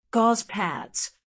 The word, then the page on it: gauze pads: American English